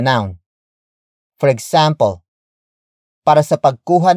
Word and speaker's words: pagkuha: Word for word, it's para sa pagkuha ng litrato. – para, sa, pagkuha, ng, litrato, para, sa, pagkuha, ng, litrato.